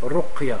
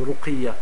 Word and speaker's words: رقية: on dit رقية c'est-à-dire sur le qaf on met une ar الرقية parce qu'il y a beaucoup de gens ils prononcent mal ils disent par exemple euh, je veux qu'on fasse une ruqiyah il met une kasra sur le, sur le qaf. Une ruqiyya, on ne dit pas une ruqiyya.